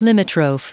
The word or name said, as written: limitrophe